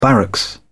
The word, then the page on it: barracks: British English